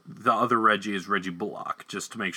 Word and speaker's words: Bullock: And he told them it's Bullock, as in lock, like you lock a door.